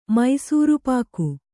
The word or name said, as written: ಮೈಸೂರ್‍ಪಾಕ್